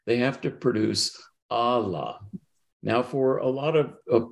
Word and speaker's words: Allah: Now, in, almost everybody who works on the Sufis would spell that A-L-L, long A-H, okay? And so Allah. But the problem is in Bengali, you can't produce that sound. If I were to transliterate the Bengali, it would be pronounced Ola.